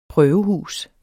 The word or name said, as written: prøvehus